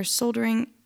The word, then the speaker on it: soldering: I don't actually know if it's soldering or soldering.